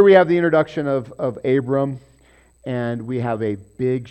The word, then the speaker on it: Abram: Now Terah lived 70 years and begot Abram, Noah, and Haran. Okay, so Abram, we're going to pause there because he's the important guy. And again, there's no dot in there, so you might pronounce it Avram.